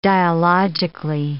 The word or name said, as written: dialogically